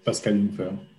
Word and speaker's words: Pascal Jungfer: And you pronounce it well, Pascal Jungfer.